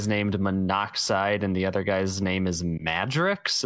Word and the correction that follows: Madrox: I think it's Madrox.